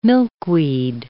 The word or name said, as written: milkweed